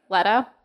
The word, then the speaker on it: Leto: How do I say his name? – Leto.